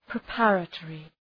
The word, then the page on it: preparatory: prı’pærə,tɔ:rı